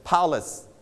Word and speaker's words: palace: I put the pink color in the first syllable ครูใส่สีชมพูไว้ให้ที่พยางค์แรกนะครับนักเรียนปลายทางด้วยแสดงว่าคำนี้ stress หรือลงเสียงหนักที่พยางค์ที่หนึ่งนะครับเราจะอ่านว่า palace